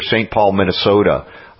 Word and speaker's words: St. Paul, Minnesota: And folks, again, please, I encourage you all, if you're anywhere near or want to be near St. Paul, Minnesota, for June 10th and 11th. Did I say that right this time? – Yes.